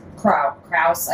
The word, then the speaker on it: Kraus: But I wanted to say, because this is kind of interesting, Daniel Cross is... Kraus, I think, is probably the right pronunciation. It's K-R-A-U-S.